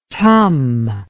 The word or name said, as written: tum